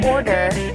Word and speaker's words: order: The word order has this O sound, followed by a tiny R sound, like or, while odor contains this O sound.